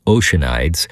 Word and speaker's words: Oceanides: It's pronounced all sorts of ways. Usually in America, it's Oceanides.